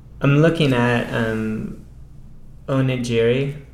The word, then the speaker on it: onigiri: You can if you look on YouTube and type in it, I think the Japanese pronunciation would be onigiri.